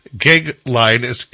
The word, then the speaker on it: Goeglein: It's Timothy, and his last name's Geoglein, and it's spelled G-O-E-G-L-E-I-N.